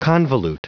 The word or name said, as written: convolute